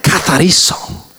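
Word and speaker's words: Katariso (καθαρίζω): Katariso. That almost sounds like chorizo. That's Greek, not Spanish. So I almost said it kind of Greekish, like Greek with a Spanish accent, huh?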